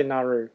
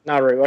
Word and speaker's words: Nauru: It depends. We say Nauru. Nauru, which I think is right.